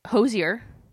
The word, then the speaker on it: Hozier: So, Hozier... which is the correct pronunciation of his name. I looked it up to double check.